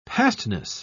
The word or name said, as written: pastness